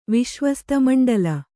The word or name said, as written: ವಿಶ್ವಸ್ತಮಂಡಲ